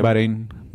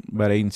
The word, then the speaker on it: Bahrein: Va, ¿Cómo se pronuncia? – Bahrein. – ¿Bahrein? Bahrein, sí.